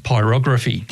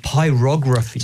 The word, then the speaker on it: pyrography: Pyrography. – Pyrography.